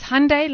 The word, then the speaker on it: Hyundai: What is it then? – It's Hyundai like Sunday.